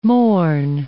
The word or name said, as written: Mourne Mountains